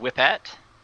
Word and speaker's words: Whipette: W-H-I-P-E-T-T-E. – All righty. – Would you like to read it? – I think it's going to be pronounced Whip At. Whip At.